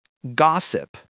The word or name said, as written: gossip